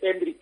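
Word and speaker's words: Endrick: Oye, has dicho Hendrik, para que aprendamos ya a pronunciarlo, es Hendrik, ¿no? – Sí, es Hendrik. Aquí todas las vocales al final se dice una I al final. Entonces, aquí dicen que es Hendrik.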